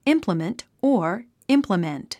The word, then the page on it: implement: ímpləmənt; インプラメント